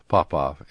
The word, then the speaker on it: Papa: Papa, equal emphasis on both syllables.